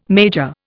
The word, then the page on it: major: Indian